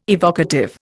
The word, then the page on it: evocative: Indian